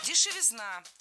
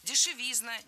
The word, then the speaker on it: дешевизна: Дешевизна – тоже неверно. В этом слове, опять же, ударным должен быть суффикс «изн». Дешевизна – дешевизне. И, кстати, парой эти слова запомнить удобнее. Дешевизна, дороговизна, ударение однотипное.